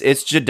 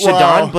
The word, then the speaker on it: Jaudon: It's Jadon. – Did I just say it wrong? – Yeah, it's Jadon, but I always like it's my go-to cut to him.